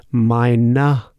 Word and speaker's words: meiner: Keep in mind, the ER at the end in German is pronounced like a, like an A. So we pronounce it meiner Tochter.